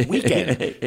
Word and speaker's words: Weeknd: Or how to pronounce Weekend. W-E-E-K-N-D. – Correct.